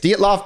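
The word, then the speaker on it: Dyatlov: Well, this goes back to when we were talking about the boy, I'm going to butcher this name, the Dietlov, is that what it's called? – Yes, Dietlov Pass.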